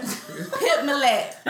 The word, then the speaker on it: Pip Millett: Pip Millett.